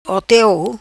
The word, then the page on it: Oteu: patua